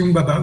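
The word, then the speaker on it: Humbaba: il y a comme, si je regarde, um, Umbaba, je ne sais pas si c'est comme ça qu'on le prononce. – Oui, oui, ça se dit aussi Umbaba, oui.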